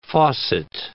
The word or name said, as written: faucet